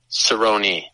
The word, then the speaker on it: Cerrone: We're going to have a better meeting somewhere down the line, but how do you pronounce the Cowboys' last name? Is it Cerrone or Cerrone? – Cerrone.